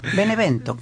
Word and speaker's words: Benevento: No, ahí lo pronunciaste mal. Benevento. Normal.